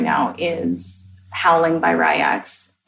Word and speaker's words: RY X: Thank you for finally telling me how to say his name, Ryax. I'm like, R-Y-X. – I'm pretty sure it's Ryax.